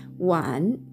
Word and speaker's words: 晚: wan」というふうに発音します発音記号は WAN 第三声低く沈む音になりますなので「ャンも「ャンも成長の組み合わせとしては同じ第 3 声そして第 4 声の組み合わせになります。